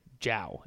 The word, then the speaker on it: Gyau: It's Gyau, but it's Jow.